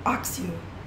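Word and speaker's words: Accio: Minha pronúncia de Akio sempre foi Akio, por causa dos filmes. Mas o Stephen Fry pronuncia Axio nos audiolivros, audiolivros em inglês.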